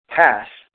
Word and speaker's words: pas: The Greek word would be pas, P-A-S. And it's pronounced almost identical with P-A-S-S, but you don't stretch out the S as long. So it's not pass, but it's pass.